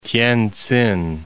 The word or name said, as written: Tientsin